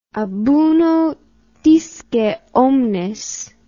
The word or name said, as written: ab uno disce omnes